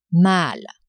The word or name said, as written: mal